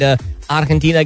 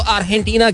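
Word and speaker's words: Argentina: pronounce नहीं करते जो स्पेनिश होते हैं उसको वो खा की तरह प्रोनाउंस करते हैं हा हा अर्जेंटीना राइट जे जो होता है उसको वो खा कहते हैं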